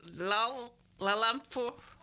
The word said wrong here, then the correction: Dalalamfor: Oh, Dalalamfor.